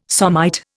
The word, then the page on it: somite: Indian